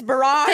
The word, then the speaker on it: barrage: It is barrage. – Thank you. – Hold on. Barrage. – Barrage. I did say it right. – You were correct.